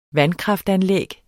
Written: vandkraftanlæg

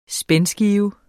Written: spændskive

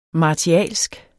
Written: martialsk

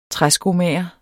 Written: træskomager

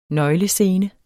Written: nøglescene

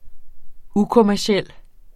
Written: ukommerciel

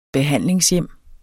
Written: behandlingshjem

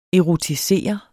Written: erotisere

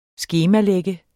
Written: SKEMALAGT

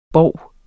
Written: BOVENES

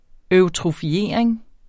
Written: eutrofiering

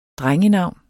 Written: drengenavn

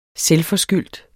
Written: selvforskyldt

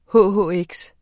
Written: hhx

